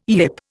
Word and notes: yip: Indian

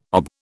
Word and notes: OB: British English

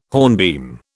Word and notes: hornbeam: British English

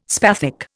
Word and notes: spathic: Indian

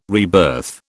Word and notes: rebirth: British English

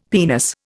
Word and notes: penis: Indian

